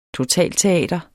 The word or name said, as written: totalteater